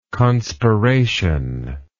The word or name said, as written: conspiration